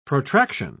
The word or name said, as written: protraction